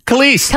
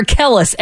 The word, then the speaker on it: Kelis: Kelis. – I don't know why I have so much trouble with that. I want to call her Kellis every time.